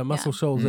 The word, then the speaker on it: Muscle Shoals: It's a meteor shower near Muscle Shoals, if I said that right. – Oh, yeah, Muscle Shoals.